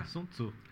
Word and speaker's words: Sun Tzu: E é muito interessante que uma das coisas do, do Sun Tzu, é Sun Tzu mesmo? – É, Sun Tzu. – É Sun Tzu é a pronúncia.